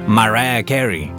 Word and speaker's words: Mariah Carey: Estoy hablando de Mariah Carey, o como lo pronuncian ellos, Mariah Carey.